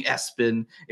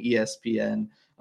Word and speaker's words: ESPN: Everybody bullied me away from saying Espen into saying ESPN.